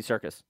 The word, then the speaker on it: Serkis: Oh, I said Andy Sykes. Sykes? – Andy Serkis. – Serkis.